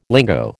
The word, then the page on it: lingo: American English